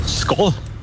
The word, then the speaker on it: Skål: Skål. – Wow. Richtig? – Echt? – Ja. – Oh. – Du hast sogar richtig betont. – Oh. – Wird Skal geschrieben, Skål, aber ausgesprochen.